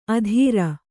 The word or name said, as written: ಅಧೀರ